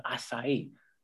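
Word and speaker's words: açaí: And I was like, oh, I want to get an acai bowl. And he's like, no, it's called acai. What? Acai.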